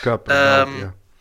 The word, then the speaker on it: Kuiper: So Amazon Kuiper, or I don't know how you pronounce it in English. – Kuiper.